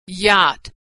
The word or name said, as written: yacht